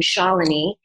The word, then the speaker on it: Shalini: So my first name, Shalini. And since I moved to Miami, Florida, with the Hispanic accent, a lot of people would pronounce it Chelani or Shalini.